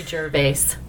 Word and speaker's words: Gervais: Ricky, I won't say his name right. – Gervais.